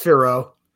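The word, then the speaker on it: Fiorot: Caitlin Chookagian versus Manon Farrow. Chookagian's 18-4. She's won her last four fights. Manon Farrow, or how do you say it, is... – Just Firo.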